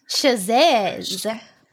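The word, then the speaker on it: Chasez: Is it Chavez? How do you pronounce his last name? – Chazez. – Oh my gosh. Okay. He's JC. I can't say that. I've been calling this man, Chavez for years.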